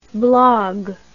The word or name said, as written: blague